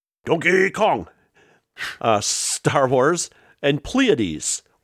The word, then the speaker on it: Pleiades: Yeah, it's Pleiades. Pleiades. I took an astronomy class in college. It's Pleiades. – There's a King's X song called Pleiades, and if King's X pronounces it Pleiades, it's Pleiades.